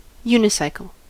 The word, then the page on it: unicycle: American English